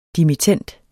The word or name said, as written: dimittend